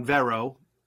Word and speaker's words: Vero: Over the course of a week, Zack Snyder on Vero, which is pronounced like Mera, from Aquaman, was dropping these pictures from his version, from Zack Snyder's Justice League, one a day, and today was Superman Day.